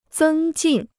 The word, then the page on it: 增进: zēng jìn